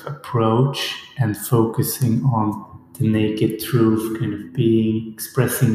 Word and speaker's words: NAKD: You mentioned the name that you pronounce it naked. It's spelled N A K D.